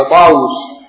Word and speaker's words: طاووس: قال عن طاووس هكذا بواوين، طاووس ربما كتبت بواو واحدة طاووس لكن تنطق طاووس، سيأتي معنا سبب ذلك.